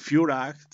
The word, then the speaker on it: Führer: del Führer o del Führer como se pronunciaría en alemán y con sus, eh, bueno, intentando pronunciar el, alemán como, como, lo hacen ellos, la terminación ER, en A, Führer, pero bueno, no hay ningún acento alemán en esto, ¿no?